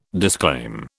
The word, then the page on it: disclaim: British English